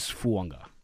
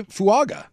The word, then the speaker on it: Fuaga: And by the way, I know it is spelled Fuaga. There's a silent N just like Tua Tagovailoa. It is Fuanga. – There's a I would say Fuaga. – It's not Fuaga. – Oh, it's Fuanga. – It's Fuanga. – Fuanga.